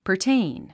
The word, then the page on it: pertain: pərtéin; パァテイン